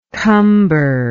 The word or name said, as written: cumber